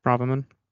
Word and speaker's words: Braverman: Suella Braverman, I think that's how you pronounce her last name. I do apologize. That's right. – Yeah. – Braverman.